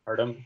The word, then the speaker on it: Hartum: Hartum, H. – So Hartum. – exactly how it's okay. I thought I was gonna pronounce it like as like Hartoom or something with my luck.